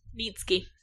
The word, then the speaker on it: Neitzke: N-E-I-T-Z-K-E. – I wasn't ready. – Nitsky. Nitsky? – N-E-I. – Nitsky. – Hang on, spell it again. – N. E-I-T-Z-K-E. – Nitsky. – Yep, she's right.